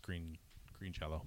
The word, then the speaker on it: Green Jelly: Although the article I was reading was saying despite the change in the spelling of the name, it's pronounced the same, supposedly. – Oh, yeah. They say it is still pronounced Green Jello.